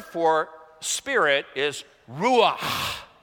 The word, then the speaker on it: ruach: Ruach, that's a guttural there. You're supposed to pronounce it like someone's choking you to get it accurate in Hebrew. Ruach, you know.